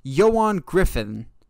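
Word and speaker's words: Ioan Gruffudd: But it is Yoan Griffin. Griffin? Griffin, I think. Yes, it's pronounced differently than it's spelled. It looks weird, but it's Yoan Griffin.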